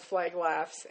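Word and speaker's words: Flag Laffs: There is a Facebook page called Flag Laughs, and it's spelled FLAGLAFFS, which looks like Flagstaff, but it's Flag Laughs.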